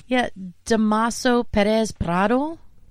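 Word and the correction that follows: Dámaso: Damaso. – Oh, it's not Tomaso. – No, Damaso.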